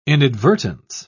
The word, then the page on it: inadvertence: British English